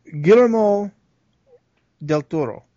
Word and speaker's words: Guillermo del Toro: The American is Guillermo del Toro.